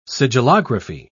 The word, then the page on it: sigillography: British English